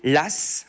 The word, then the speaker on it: Law: Martial Débrief, écrivain en France, on va faire confiance à un, banquier, LAS. Alors ça s'écrit L-A-W, mais on le, dit bien de cette façon, LAS.